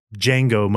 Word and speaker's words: Django: Dave Juan Moten. – No. – Dave Django Moten. – That's it. – The D is silent.